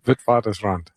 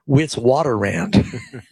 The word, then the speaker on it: Witwatersrand: Witwatersrand. – Yeah, because you speak a little Afrikaans. For me, it's Witwatersrand.